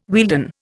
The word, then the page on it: Wealden: Indian